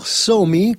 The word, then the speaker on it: Somi: Sì, il papà della signorina chiamata Somi, questa è la pronuncia esatta, no? – Sì, credo di sì.